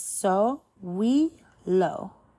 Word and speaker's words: Sowilo: Phonetic value is S. Pronunciation is so we lo.